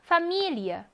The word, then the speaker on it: Familie: 여기에 강세가 있는 경우는 이어로 발음을 한다라고 했어요. 읽어볼까요? Familie.